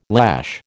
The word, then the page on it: lash: American English